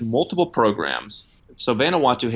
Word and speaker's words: Vanuatu: Vanuatu or something? – Yeah, Vanuatu. – Vanuatu.